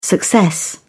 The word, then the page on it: success: British English